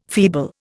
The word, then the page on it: feeble: Indian